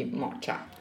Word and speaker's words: mocha: No, eh, si scrive M-O-C-H-A, quindi moccia.